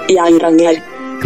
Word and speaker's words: Geiranger: è scritto Geiranger. Come si pronuncia? – Geiranger.